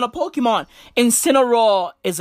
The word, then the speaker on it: Incineroar: It's spelled I-N-C-I-N-E-R-O-A-R. Incineroar.